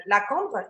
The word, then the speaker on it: La Cambre: La Cambre, Yeah, am I pronouncing that mm-hmm. right? Um, yeah, yeah.